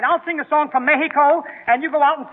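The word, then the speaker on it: México: Oh, no, not Mexico, Eddie. The Spanish pronunciation is Mexico. – Yeah? – You make the X sound like an H, you see? Mexico.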